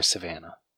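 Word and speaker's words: Sivana: 2 years after Thaddeus Savannah's defeat, is that how you say his name? Is it Savannah or Savannah? – Savannah. – Okay, just double checking.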